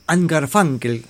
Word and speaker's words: Garfunkel: Garfunkel, se dice con A en vez de con U, esas cosas de pronunciación del inglés, aunque a nosotros nos resulta muy extraño.